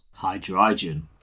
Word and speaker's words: Hydreigon: Hydreigon.